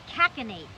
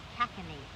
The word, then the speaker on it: cachinnate: Cachinate. – Spell it for me, please. – C-A-C-H-I-N-N-A-T-E. Cachinate.